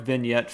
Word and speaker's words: vignette: The dark vignette filter, or how do you say that? Was it vignette? – Vignette.